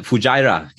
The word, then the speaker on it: Fujairah: Fujairah, genau, Fujairah, ich habe es noch offen gehabt.